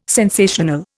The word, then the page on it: sensational: Indian